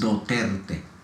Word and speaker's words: Duterte: Duterte.